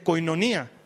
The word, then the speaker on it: koinonía: Y la palabra comunión viene de una palabra griega que han debido escuchar alguna vez que se, que se pronuncia koinonía, que eso es fácil de pronunciar.